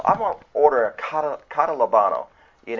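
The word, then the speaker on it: catalabano (καταλαμβάνω): That comprehending word, it's spelled K-A-T-A-L-A-B-A-N-O. And it pronounces like catalabano, catalabano, catalabano.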